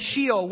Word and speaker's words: Sheol: Okay, Sheol.